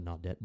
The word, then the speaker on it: Naudet: I think it's the Naudet brothers is how you say their name, that they followed Battalion Chief Joseph Pfeiffer on 9/11 that day.